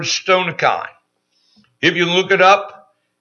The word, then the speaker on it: stoicheion: I'm going to spell it, S-T-O-I-C-H-E-I-O-N, stonikein.